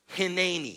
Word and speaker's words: Hineni: Hineni.